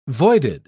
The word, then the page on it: voided: British English